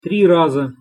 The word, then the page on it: tri raza: Russian